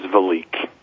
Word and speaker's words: VeliQ: It is VeliQ.